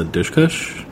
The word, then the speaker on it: Dushku: I think her name that you said, is Eliza Dushkush? How do you pronounce her last name? – Dushku.